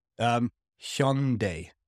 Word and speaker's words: Hyundai: How do Koreans pronounce it again? – Hyundai, like H-Y-O-N or H-Y-E-O-N.